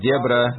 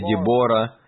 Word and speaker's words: Дебора: Ну, по-разному произносят это имя. Некоторые говорят Дебра, некоторые Дебора. Но я в любом случае я пойму, о ком идет речь, если вы скажете так или иначе.